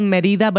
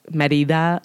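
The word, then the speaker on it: Mérida: It's very, you know, and it's called Merida, but it's spelled Merida, guys. Yeah. So if you're going to look this up, it's Merida, Merida.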